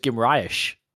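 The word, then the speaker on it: Guimarães: I thought it was Guimaraes, but...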